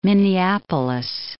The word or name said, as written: Minneapolis